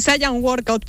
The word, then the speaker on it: Saiyan Workout: A ver, primero dime si lo he dicho bien, porque lo mismo te estoy presentando fatal. – Sí, Saiyan Workout, pero lo puedes pronunciar como tú quieras, lo podemos Saiyan españolizar Workout.